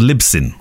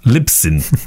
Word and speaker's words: Libsyn: It's called Libsyn. Okay, it's say it again. – Libsyn, Libsyn, Libsyn.